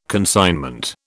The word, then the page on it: consignment: British English